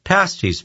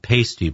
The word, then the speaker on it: pasties: Pasties, pronounced not pasty, by the way.